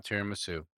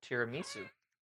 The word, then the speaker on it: tiramisu: Not tiramisu. – No, tiramisu. – You put the wrong emphasis on the wrong syllable.